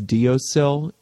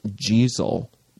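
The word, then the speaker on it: deiseal: It's actually pronounced jeezel, but nobody will know what the heck you're talking about if you say, oh, are we going jeezel? They'll say, what? Diosil is how it's spelled. So most people, English-speaking people, will say diosil, but the Gaelic is pronounced jeezel.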